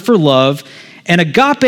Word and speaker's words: agape: There's another common word for love in the Greek that's pronounced agape.